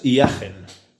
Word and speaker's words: Hiagen: El nombre concreto es Jagen.